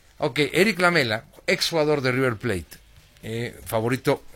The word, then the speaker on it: Lamela: El caso de Eric Lamela, el jugador argentino. – La importancia de la sílaba tónica, ¿verdad? – ¿Por qué? – No, Eric Lamela, ¿no? Se ha pedido así. – Sí, Lamela.